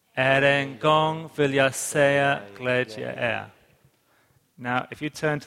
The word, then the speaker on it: glädje: Glädje alltid i Herren. – En gång vill jag säga glädje är. I don't know if I'm saying it right. – Yeah, that's good.